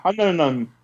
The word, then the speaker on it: Hunnam: Hunnam.